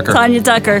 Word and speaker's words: Tanya Tucker: It rhymes with Rania Rucker. – There you go. There you go. Glenn Campbell's ex. – There you go. – Tanya Tucker.